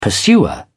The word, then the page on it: pursuer: British English